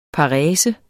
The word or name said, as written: parese